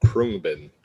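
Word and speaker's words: Khruangbin: It's pronounced like Krungbin.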